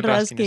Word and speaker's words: Ruskin: Nos presentas Sésamo y Lirios, una edición de la que te has hecho cargo sobre la obra de John Ruskin o Raskin. No sé muy bien cómo pronunciarlo. – John Ruskin, está muy bien.